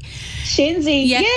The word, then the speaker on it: Shenzi: Is it Shenzi? Is that how you pronounce it? Shenzi? – Shenzi. Yeah.